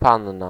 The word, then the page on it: demoiselle: /də.mwa.zɛl/